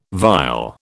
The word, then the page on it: vile: British English